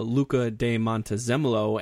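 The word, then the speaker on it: Luca di Montezemolo: You know, this, I read a big article in Formula One Racing about Luca de Montezemolo and how, I think I said it right.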